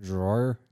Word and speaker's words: drawer: How's it pronounced? – Drawer. – Drawer.